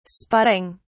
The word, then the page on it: spotting: British English